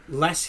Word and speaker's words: Loess: But you mentioned Luss Hills, which the way, if you wanted to look that up, it's spelled Loess, correct? – Yes, sir.